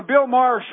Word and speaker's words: Maher: It was on the Bill Maher show, right? – Yes.